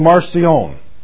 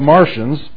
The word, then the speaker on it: Marcion: One of the problems that we had, there was a rascal in the second century called Marcion. And I like to mispronounce it and say our problem is that we have Martians.